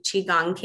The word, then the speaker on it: Qigong: Um, Qigong is how we pronounce it.